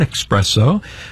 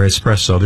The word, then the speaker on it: espresso: 95.7 WZID, wrapping up another long set of today's best music with Sabrina Carpenter right there, and Espresso, or Espresso, there's no X there, right? – Well, there is an X there. – There's an X there? – Oh, okay. But you just don't pronounce it. – We don't pronounce it, yeah.